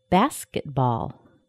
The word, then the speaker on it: basketball: basketball, basketball, basket. Basket is the first word. Ball is the second word. Ball is a secondary stress.